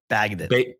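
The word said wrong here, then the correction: Bagent: Bajent. Bajent is how you say it.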